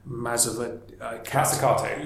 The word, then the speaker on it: Mazzucato: I mean, I read a quote that was by Mariana Mazzucato.